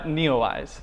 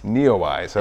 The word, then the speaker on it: NEOWISE: When there's certain events in the sky, so the comet was just by here, and I've been calling it Comet Newsy. Am I saying it right? – Neowise. – Neowise, okay.